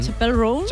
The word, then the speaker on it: Chappell Roan: Ella misma nos explica cómo se pronuncia. – Chappell Roan. Yeah? Okay. Ah, pues lo digo mal yo. – Chappell Roan. – Apple, Apple.